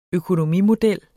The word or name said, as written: økonomimodel